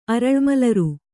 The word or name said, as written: ಅರಳ್ಮಲರು